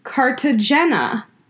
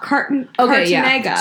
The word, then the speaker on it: Cartagena: But it's a Colombian show set in Cartagena. Not Cartagena.